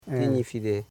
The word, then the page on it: kinifide: ki.ni.fi.de